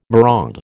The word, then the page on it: maraud: American English